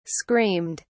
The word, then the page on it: screamed: British English